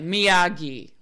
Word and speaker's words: Miyagi: And he's like, Miyagi.